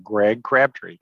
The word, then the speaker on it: Greg Crabtree: And Greg, I did not ask you before we started recording how to properly pronounce your name, but I'm going to guess it is exactly as it's spelled there. Did I get it correct? – It is correct, Greg Crabtree.